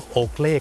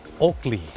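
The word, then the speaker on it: Oakley: คนไทยออกเสียงว่าโอเกลแต่เจ้าของภาษาไม่ได้ออกเสียงว่าโอเกลครับเขาออกเสียงว่าโอกลีโอกลี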